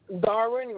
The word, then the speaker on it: Darwin: Darwin Reyes Rodriguez. – Okay, and can you spell that? – D-A-R-W-I-N.